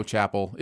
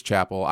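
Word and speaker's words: Chappell: It's supposed to be pronounced Chappelle. – Chapel. It's Chapel. I just get it wrong.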